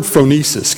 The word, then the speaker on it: phronesis: I want to say the word phronesis. Can you say phronesis? Phronesis. And phronesis, spelled with a P-H-R-O-N-E-S-I-S, shows up in this little letter, some form of it, usually a verb or participle form, 10 times.